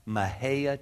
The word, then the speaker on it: Mexia: Now we'll forget, we went to First Baptist Church, Mejia, Texas. It's spelled M-E-X-I-A, Mexia, but we call it Mejia.